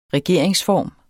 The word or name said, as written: regeringsform